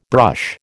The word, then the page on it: brush: American English